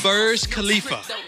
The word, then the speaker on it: Burj Khalifa: It's called the Burj Khalifa.